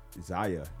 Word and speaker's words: Zaya: As everybody's seen in the news this week, Dwayne Wade's daughter, well, she transitioned to be his daughter, Zaya. That's how you pronounce her name.